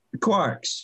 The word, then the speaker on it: Qwarks: Qwarks, yeah.